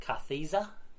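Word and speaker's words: Kartheiser: So referring to Vincent Carthiza, that's how you pronounce it.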